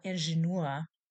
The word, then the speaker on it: Ingenieur: Der Ingenieur, die Ingenieure, male engineer, French pronunciation.